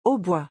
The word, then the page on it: hautbois: French